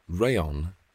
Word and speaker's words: rayon: You know, rayon rhymes with crayon.